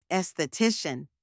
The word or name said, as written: esthetician